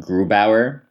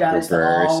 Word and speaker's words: Grubauer: I think his last name is pronounced Grubauer because he's German and not. – It's probably Grubauer. – So not, he's not French-Canadian, so it's not Gruber, so it's probably Grubauer.